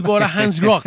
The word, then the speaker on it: Bora Hansgrohe: Del Red Bull Jora Hansgrohe. – ¿Cómo? – Red Bull Bora Hansgrohe.